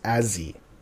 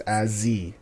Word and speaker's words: Azee: If you're saying his name with the short form, it's Azzy. Okay? Yep. So, it's Azzy with, you know, A-Z-E-E.